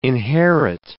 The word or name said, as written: inherit